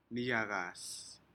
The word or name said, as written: niyacas